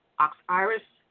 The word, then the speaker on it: Oxiris: Her first name is spelled, it's really weird, O-X-I-R-I-S, Oxiris Barbeau, B-A-R-B-O-T, Dr. Oxiris Barbeau.